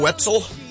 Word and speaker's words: Wetzel: Wetzel? – Yeah. – Rhymes with Joe Pretzel? – Yes, it does.